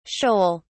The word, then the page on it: sheol: British English